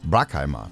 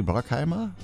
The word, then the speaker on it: Bruckheimer: Bruckheimer? Ich glaube, das wird jetzt amerikanisch, da wird das UVA ausgesprochen. Ne? Jerry Bruckheimer, würde ich mal so sagen.